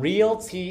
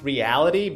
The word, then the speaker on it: Realty: No, but we're actually going to be talking about Realty Income, ticker symbol O. Now, I know it looks like this company is pronounced Reality, but it's actually just Realty.